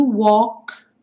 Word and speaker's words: walk: Seguindo, why do stars fall down from the sky every time you walk by? Esse AL do walk vai ter um som como de um O com acento agudo.